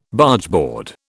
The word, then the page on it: Bargeboard: British English